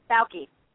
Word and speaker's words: Bauke: Bauke.